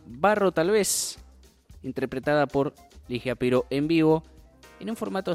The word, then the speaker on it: Ligia Piro: Estamos hablando de Ligia Piro,